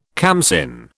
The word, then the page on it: khamsin: British English